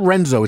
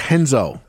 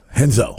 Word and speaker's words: Renzo: The R in Portuguese is like an H. It's not Renzo. It's Henzo. – Henzo.